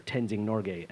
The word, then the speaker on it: Tenzing Norgay: No, is that the wrong person I'm thinking of? – Yeah, that's how you pronounce it. – That's his name, Tenzing Norgay.